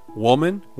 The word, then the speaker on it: woman: E dessa vez tem uma diferença na pronúncia. Woman, women.